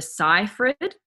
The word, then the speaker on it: Seyfried: The name I was pronouncing her name originally, I was saying Amanda Seyfried or Seyfried, but it's Amanda Seyfried.